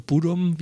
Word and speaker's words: Bodom: und Children of Bottom äh, oder Bodom, wie es, äh, glaube ich, richtig ausgesprochen wird, äh, stechen da immer noch mal so ein bisschen raus.